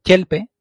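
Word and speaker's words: tyelpe: Por ejemplo, sabemos que el Cuenya tiene un dígrafo que es TY, por ejemplo, en la palabra chelpe, que es plata. Sabemos que eso se pronuncia con una T palatalizada, che, que es bastante difícil para los castellanohablantes que no hablen otros idiomas donde sí se usa.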